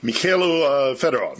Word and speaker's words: Mikhailo Fedorov: Who is and what's the situation with Mikhailo Fedorov? Am I saying that right? – Mikhailo Fedorov, yes.